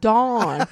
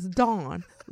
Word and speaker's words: Dawn: No, Dawn. So now I just you better not have to spell it for them. – I just cut them off at the pass. Dawn, like the morning. – You mean D-O-N? – Yes, and they do that too.